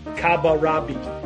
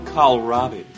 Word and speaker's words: kohlrabi: Mine would be, "Try to pronounce this. Kabarabi." Did I say that right? – No. – Oh, so the joke's. It's kohlrabi.